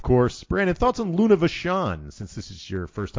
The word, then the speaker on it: Vachon: How did they pronounce it, Chris? It was French. – Vachon? – Was it Vachon? Vachon.